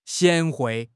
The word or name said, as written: 贤惠